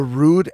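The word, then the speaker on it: Rue'd: This is called the Rude Attitude, and it's spelled RUE apostrophe D. Rude Attitude.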